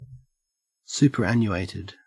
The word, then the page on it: superannuated: Australian English